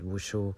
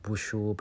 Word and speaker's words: Wushu: And after that, there was a whole demonstration of Chinese Mousuet Wushu. I have been corrected that it's not Wushu, but it is Wushu.